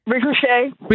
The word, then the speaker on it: Ricochet: Re- øh, hvordan udtaler man det? Ricochet? Nej. Ricochet. Ricochet. Det var sådan, det skulle udtales.